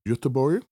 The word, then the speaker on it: Göteborg: When we were talking about Gothenburg and I was teaching you the Swedish pronunciation of that city. – Gothenburg? – Göteborg? – What? – Exactly. I said, if anybody's out there in Sweden, let me know how I did. – And? – Mike Denial says the pronunciation was impeccable.